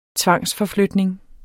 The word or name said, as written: tvangsforflytning